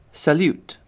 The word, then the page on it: salute: British English